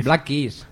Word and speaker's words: Black Keys: Black Keys.